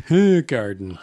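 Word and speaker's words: Hoegaarden: Holy Garden. – I think it's pronounced Hoo Garden.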